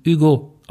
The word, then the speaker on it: Hugo: Äh, es gibt ein Geschwisterpärchen, nämlich Amicia und ihr Bruder Hugo, beziehungsweise im französischen Hugo ausgesprochen.